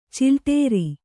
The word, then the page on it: ಚಿಲ್ಟೇರಿ: cilṭēri